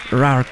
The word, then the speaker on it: Roar: Sí se dice así, ¿no? Roar. O Roar, Roar. Como Roar. Así. Roar. Así con la... Ajá, con el paladar.